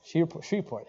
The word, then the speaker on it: Shreveport: And am I saying that right? Yeah, I think it's Shreveport. Yeah, there we go.